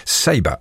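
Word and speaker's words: Ceiba: The track is called Ashes, taken from the album released on the 2nd of October called Seiba. That's C-E-I-B-A. Yes, I checked its pronunciation. Seiba is the album.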